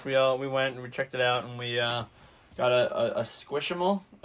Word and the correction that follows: Squishmallow: Am I saying that right? – Squishmallow.